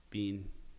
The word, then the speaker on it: Behn: Scandinavian writer Ari Bain, how do you say that? B-E-H-N? – Bean.